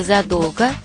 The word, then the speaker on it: задолго: Как правильно, задолго или задолго, надолго или надолго, ненадолго или ненадолго? Запомнить легко. Во всех этих словах ударение такое же, как у слова «долго».